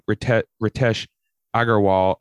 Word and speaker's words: Ritesh Agarwal: The first one is, and correct me if I'm pronouncing any of these names wrong, Ritesh Agarwal of OYO Rooms, right? – Yep.